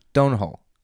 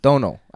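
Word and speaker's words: Domhnall: Did you not say his name? Donal. – Not Donal. I don't know how to say his name. – Donal. I think it's Donal. Donal Gleason.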